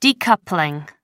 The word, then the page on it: decoupling: American English